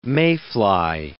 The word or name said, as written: mayfly